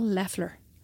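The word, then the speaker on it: Loeffler: Leffler, apparently, is the pronunciation.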